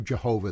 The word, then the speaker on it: Jehovah: So we have, and I'm going to stick to Jehovah, that pronunciation.